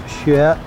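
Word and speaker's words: xue (雪): It's pronounced xue.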